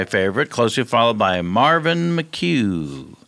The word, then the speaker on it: McHugh: Every listener has heard the name differently. In my mind, it was McCune. – The name is spelled capital M-C. Capital H-U-G-H, Marvin McHugh. – Marvin McHugh. All right. Well, she spelled it slightly different, but the same pronunciation, I believe.